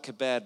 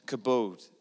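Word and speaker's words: kabod (כָּבוֹד): And the word for glory comes from the Hebrew word that's spelled kebed, but it's said kabod.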